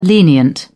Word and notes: lenient: British English

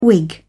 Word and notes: wig: British English

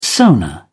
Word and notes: sonar: British English

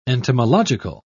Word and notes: entomological: British English

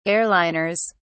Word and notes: airliners: British English

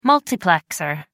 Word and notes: multiplexer: American English

